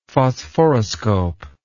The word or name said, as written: phosphoroscope